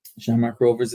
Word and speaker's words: Shamrock Rovers: Am I pronouncing it correctly? – Shamrock Rovers is right, absolutely.